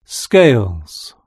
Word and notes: scales: British English

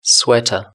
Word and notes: sweater: British English

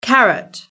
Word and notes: carrot: British English

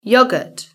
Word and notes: yoghurt: British English

